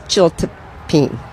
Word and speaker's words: chiltepín: Yeah, it's pronounced Chiltepín, but it's C-H-I-L-T-E-P-I-N.